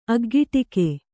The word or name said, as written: ಅಗ್ಗಿಟ್ಟಿಕೆ